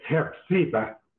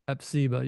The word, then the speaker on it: Hephzibah: It was the land, and the land being from Isaiah, 62:4, I believe, where it refers to the land as being Hezbollah, let me just look at it real quick so I say it correctly. – I think it's Hepzibah. – Hepzibah, yeah, Hepzibah or whatever, which is the name of the mother of Manasseh.